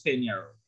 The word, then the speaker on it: Peñarol: P-E, it's spelled Peñarol, but it's pronounced Peñarol.